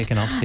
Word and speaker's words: Echinops: Echinops? Am I? – Yeah. – I'm not sure I'm pronouncing these correct. – The Echinops, yeah.